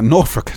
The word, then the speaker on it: Norfolk: So Guy Lestrange was born in 1048 in Norfolk. I said that right, didn't I? – Yeah, Norfolk. Norfolk.